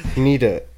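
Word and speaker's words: Hnida: It's H-N-I-D-A. Is the H silent? Or is it like Nida? Nida. – I'm going to go with a silent H there.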